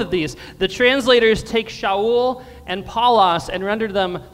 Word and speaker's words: Shaul: His Hebrew name is Shaul. Let me hear you all say Shaul. Excellent job. That's how Saul was pronounced in Hebrew or Aramaic, Shaul.